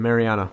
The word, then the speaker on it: Mariana: Is that how you pronounce it? – The Mariana.